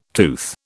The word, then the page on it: tooth: British English